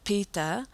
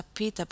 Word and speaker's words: PETA: En inglés se pronuncia pita porque sabe que nosotros vocales son medio locos y cambian, pero vamos a decir peta, ¿okay?